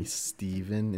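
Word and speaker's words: Stephan: My name's Steven, spelled with a PH and an A, but you can call me Steven instead of Stefan, unlike so many people do on their first attempt.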